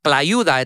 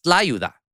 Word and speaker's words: tlayuda: It's not banchan, it's banchan. And it's not tlayuda, it's tlayuda.